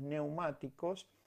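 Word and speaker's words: pneumáticos: Se escribe P como psicología, ¿vieron? Eh, P psicología, pero psicología P neumáticos es neumáticos.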